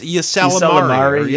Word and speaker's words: Ysalamiri: It's spelled YSA. It's Ysalamiri, I believe. – Yes, Ysalamiri.